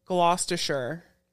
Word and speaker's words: Gloucestershire: That's in Gloucestershire.